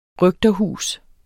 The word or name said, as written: røgterhus